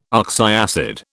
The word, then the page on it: oxyacid: British English